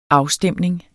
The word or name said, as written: afstemning